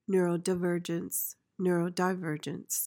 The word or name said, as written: neurodivergence